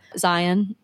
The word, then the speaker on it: Zion: Then we went down to Utah, stopped in Salt Lake City, did the Salt Flats, went down to Arches National Park, Moab, Zion National Park. That's actually how you pronounce it. Zion like lion.